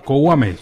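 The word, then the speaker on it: Kouamé: Sí, ahí estamos viendo imágenes de lo que fue este gran partido ante el francés de 17 años, Moïse Kouame, sí señor. – Kouame. – Sí, tiene acento en la E.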